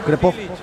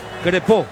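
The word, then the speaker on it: Crépeau: A ver tu pronunciación para ponernos de acuerdo. – Crespo. – Crespo. – El de Canadá. – El de Canadá, sí. 32 años. – Apellido de origen francés.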